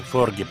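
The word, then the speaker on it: Forge: И папа Эмеритус, он же Тобиас Форге, читается Форге по-шведски.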